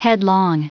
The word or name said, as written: headlong